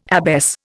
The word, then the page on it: abbess: Indian